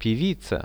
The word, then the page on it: pevica: Russian